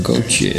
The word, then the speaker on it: Gauthier: Director Guy and Edward Gauthier. – And you've got to spell that because I don't think they're going to know how to... – Gauthier. Gauthier. It's French.